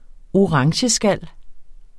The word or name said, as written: orangeskal